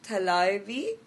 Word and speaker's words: Thalaivi: Okay, so your work in the recent film, Thalaivi, received much recognition.